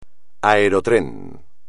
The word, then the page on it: aerotrén: espagnol